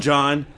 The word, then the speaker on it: Jon: I'm John, J-O-N, not J-O-H-N, so I say my name differently as well.